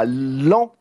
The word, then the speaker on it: Laon: c'est le premier meeting de campagne de Yannick Jadot, enfin le meeting officiel, c'était à l'an ça s'écrit de Laon, mais ça se prononce Lan.